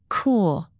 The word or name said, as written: cool